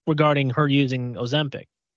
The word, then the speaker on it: Ozempic: That's how you pronounce it. – It is. It's Ozempic.